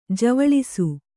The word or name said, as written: ಜ್ವಲಿಸು